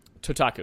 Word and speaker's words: Totaku: Totaku. It's like Kotaku, the website, but with a T.